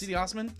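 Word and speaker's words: Cedi Osman: CeeDee Osman.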